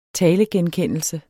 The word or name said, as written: talegenkendelse